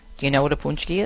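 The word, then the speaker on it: pączki: Punchki is spelled P-A-C-Z-K-I. It's not pronounced patchki. It's pronounced punchki, even though the way it looks in terms of spelling, it looks like it's patchki, but it's punchki.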